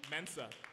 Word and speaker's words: Mensah: Mensah.